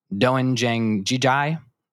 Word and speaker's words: doenjang jjigae: But apparently he was cooking, I'm going to pronounce this wrong, so apologize for my lack of Korean knowledge, doenjang jjigae, which is a stew made of soybean paste, tofu, zucchini, and scallions. And you nailed it. You nailed the pronunciation too.